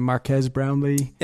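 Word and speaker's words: Marques Brownlee: And he also shouts out, before I even saw that, I saw, I don't know how you say his name, Marquez Brownlee. – Yeah, you got it.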